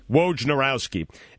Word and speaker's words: Wojnarowski: And I think the thing that really concerns me more so than that is that Adrian Wojnarowski from Yahoo Sports and no one. Did I say it right? You guys are looking at me in amazement. Did I actually say it right? – Wojnarowski.